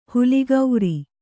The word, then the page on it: ಹುಲಿಗೌರಿ: Kannada; huli gauri